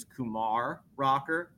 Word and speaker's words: Kumar Rocker: So the way I say his name is Kumar Rocker with the accent on the second syllable.